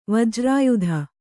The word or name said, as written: ವಜ್ರಾಯುಧ